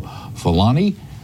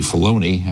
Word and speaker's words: Filoni: Filoni. And it's actually Filoni. I keep mispronouncing his name.